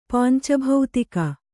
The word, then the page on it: ಪಾಂಚಭೌತಿಕ: pānca bhautika